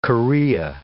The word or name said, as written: chorea